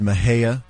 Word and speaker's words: Mexia: Like Mexia is Mejia.